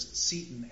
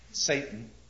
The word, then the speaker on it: Seyton: Please notice his servant's name is Seton, S-E-Y-T-O-N. Obvious, may well be pronounced with a different vowel sound. Okay, Satan.